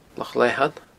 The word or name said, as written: Loch Leathann